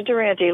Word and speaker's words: Durante: Georgia Durante, like Jimmy.